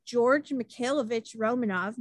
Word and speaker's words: George Mikhailovich Romanov: I can't pronounce anything, but I nailed that. I'm going to say it again. Grand Duke George Mikhailovich Romanov married Italian Victoria Romanova Betterini, which I also nailed.